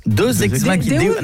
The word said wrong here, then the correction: Deus ex machina: Non, Deus ex machina. – Ah, parce que deux, j'aurais dit que c'était deux ex que t'appelles machine. – Non, ça se prononce Deus ex machina.